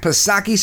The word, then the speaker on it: Psaki: Now, of course, our favorite person in the White House Communications Department, Jen Psaki-Back Psaki, silent P, of course.